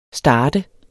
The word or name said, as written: starte